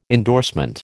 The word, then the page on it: endorsement: American English